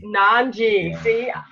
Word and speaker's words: Nanji: Nanji.